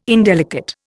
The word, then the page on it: indelicate: Indian